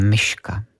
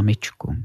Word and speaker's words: myčku: Ale zažil jsem, že neslyší anebo nedokážou vyslovit i některé jiné hlásky, takže například paní říkala, že co jí ještě chybí v kuchyni, je myška. Myslela myčku.